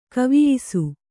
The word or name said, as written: ಕವಿಯಿಸು